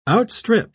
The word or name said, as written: outstrip